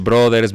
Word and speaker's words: Bros.: Ya me corrigieron la vez pasada que no se dice Mario Bros. Porque es Brothers, bla, bla.